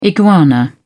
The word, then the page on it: iguana: British English